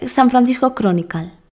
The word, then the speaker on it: San Francisco Chronicle: ¿Se dice San Francisco Chronicle? – Sí. – ¿Lo pronuncié bien? – Sí.